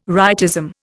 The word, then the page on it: rightism: Indian